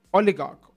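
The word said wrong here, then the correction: Ødegaard: Oligarch. – No, I'm saying his name so wrong. I'm butchering his name. I am so sorry. Odegaard.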